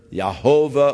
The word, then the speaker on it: Yahweh: It's the consonants that were used for the name of God, the YHVH, thought to be pronounced either Jehovah or Yahweh. And the actual pronunciation is not really known. Commonly it's Jehovah, but probably more scholarly it was Yahweh.